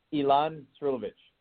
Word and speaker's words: Ilan Srulovicz: Ilhan, is it Skrulovis? Did I pronounce it right? – It's close enough. It's an impossible name. I'm trying, buddy. – It's all good. – How do I pronounce it? – Yeah. – So how do I pronounce it? How is it pronounced? – Ilhan Srilovic. – Perfect.